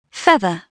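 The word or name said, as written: feather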